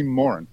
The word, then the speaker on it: Moran: We were actually talking about this, like the anglicisation of yes. Irish names. – Yes. – And how they're like pronounced incorrectly. It's actually Moran.